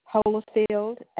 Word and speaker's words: Holifield: Patsy, P-A-T-S-Y. – P-A-T-S-Y, one moment. Your last name? – Holifield, H-O-L-I, F as in Fred, I-E-L-D.